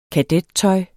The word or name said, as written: kadettøj